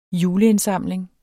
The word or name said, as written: juleindsamling